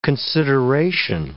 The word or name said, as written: consideration